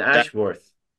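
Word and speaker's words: Ashworth: بخوایم بخونیم میشه اشورث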